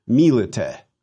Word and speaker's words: milite: Take the stem, in this case again, milit, and add the letter E, which we pronounce as a short E, milite, by, with, or from the soldier.